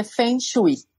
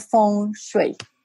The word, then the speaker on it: feng shui: Ok, entonces en español o en inglés se dice feng shui. Feng Como se escribe. – Ya, así. Fácil, básico. Pero en mandarín se dice feng shui. Feng shui. Exacto, sí, feng eh, shui.